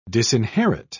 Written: disinherit

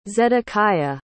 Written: zedekiah

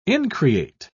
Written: increate

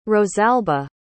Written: rosalba

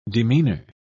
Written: demeanor

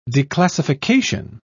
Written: declassification

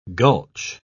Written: gulch

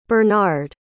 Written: bernard

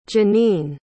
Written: jenine

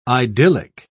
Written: idyllic

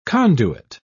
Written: conduit

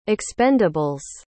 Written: expendables

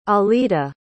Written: alida

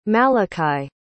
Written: malakhi

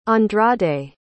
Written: andrade

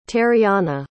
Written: teriana

